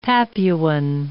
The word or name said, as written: Papuan